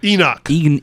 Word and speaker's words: Enoch: Enoch.